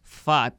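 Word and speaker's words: Pfaadt: El lanzador de los Diamondbacks, Brandon Fat, Sí, sé que debe estar pensando fat como de gordo, pero casi es que tuve que buscar que se pronuncia, pero se escribe P-H-A-A-D-T la P silenciada y la D silenciada en inglés.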